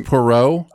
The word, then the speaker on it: Poirot: Parrot, it's Poirot.